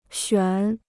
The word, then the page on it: 选: xuǎn